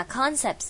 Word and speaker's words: concepts: 好， complex concepts，那 concepts 这个重音是在的的 concepts 在最前面哦，所以不要念到第二个音节了。